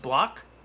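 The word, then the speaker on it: Blach: But yeah, so you have Moore, and then Wednesday they have, is it pronounced Block? – Yeah, Ty Block.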